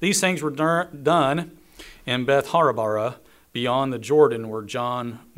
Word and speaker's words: Bethabara: And he had been baptizing, he had been immersing, he had been submerging, we're told, beyond the Jordan, there in Bethabara. That's how you say it, Bethabara.